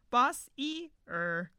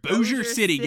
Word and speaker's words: Bossier City: We were pronouncing every letter. Boss-e-er. – Boss-e-er. It's like the Budweiser commercial, you know? – No, I don't. – Budweiser, boss eater. – So it's Bossier? – Bossier City, guys.